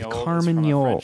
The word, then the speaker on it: carmagnole: No alternative pronunciation, so you got to go with Carmagnole.